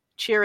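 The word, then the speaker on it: Chyr: Cheer is apparently how it's pronounced.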